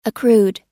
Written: accrued

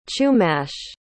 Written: chumash

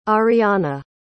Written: ariana

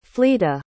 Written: fleeta

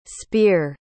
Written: speir

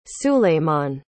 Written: süleyman

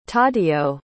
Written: taddeo

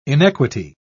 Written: inequity

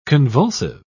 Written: convulsive

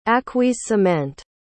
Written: acquiescement

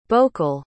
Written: bokul

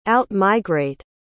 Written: out-migrate

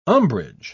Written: umbrage

